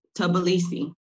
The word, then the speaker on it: Tbilisi: I mean, how do you pronounce T? Is it TB? Sibi? – It's Tbilisi. – Oh, way the fuck off. Tbilisi. Thank you for that.